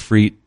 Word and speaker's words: frites: Now, you're going to see if it's spelled frite, like F-R-I-T-E-S, mm-hmm. steak. It looks like steak frites, but it's the S is silent. It's French. Mm-hmm. So it's steak frite.